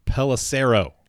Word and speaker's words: Pelissero: Pelissero.